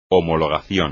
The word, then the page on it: homologación: espagnol